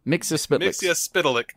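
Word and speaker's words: Mxyzptlk: Mixia Spitalik. – I think is how you say it. – Well, Mark Wade actually said it's Mixia Spitalik.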